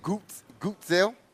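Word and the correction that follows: Guentzel: You don't even pronounce it. There's no sounding of the U in there at all. – No. – Right. – It's Gensel. – Yep. – G-I-N-Zil. – Yep. – That's how I would spell it. – Sometimes I write it down like it sounds. Okay. Down like it's spelled. – It rhymes with pencil.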